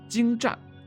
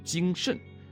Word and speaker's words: 精湛: 而当他把精湛念作精慎，赡养念作瞻仰，且在发音中被镜头拍到岿然不动这个词竟然有注音时，人们就看出他识字不多，而且根本不知道自己念的是什么。